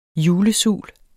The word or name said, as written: julesul